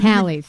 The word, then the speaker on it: Halley's: What is it? Haley's Comet every 75 years? Maybe. – Halley's.